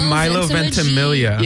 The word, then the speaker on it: Milo Ventimiglia: It's like... Milo Magdalena. – It's Milo Ventimiglia.